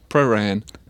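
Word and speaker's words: Prahran: e si dice qua pran. – Pran, una sillaba. – Con la A lunga. – Giusto, pran. Però tecnicamente dovrebbe essere una cosa tipo praran.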